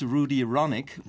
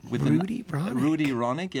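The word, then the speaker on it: Rudi Ronic: That's R-U-D-I-R-O-N-I-C, like Rude Ironic. Or Rudy Ronick.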